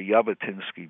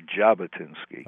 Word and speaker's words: Jabotinsky: Now, you call the guy Vladimir Jabotinsky before, but the correct pronunciation is Vladimir Jabotinsky.